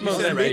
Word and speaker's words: Mozambique: It's Mozambique. – You said it right.